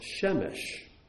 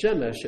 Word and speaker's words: Shemesh: But this word, Shamash, can also be pronounced Shemesh. And when it's pronounced Shemesh, it means sun, S-U-N, the source of all light on our world.